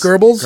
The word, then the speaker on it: Goebbels: What do we say his name Goebbels. – Is? Goebbels.